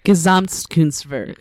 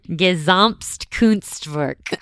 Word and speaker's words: Gesamtkunstwerk: Gesamtkunstwerk. – Perfect. Gesamtkunstwerk.